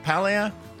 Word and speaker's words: Paglia: Paglia? Silent G.